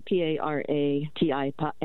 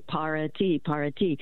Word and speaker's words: para ti: So For example, when I was studying Spanish, there's an expression for you, and it's spelled P-A-R-A-T-I. And so most English speakers will say para-ti. My Spanish teacher said, wait a minute, go in the kitchen, put on some water, and make a pot of tea. I go, para-ti.